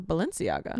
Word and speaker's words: Balenciaga: Also, is it pronounced Balenciaga or Valenciaga? – I thought it was Balenciaga. – Me too. But sometimes I swear I hear people say Valenciaga with like a V.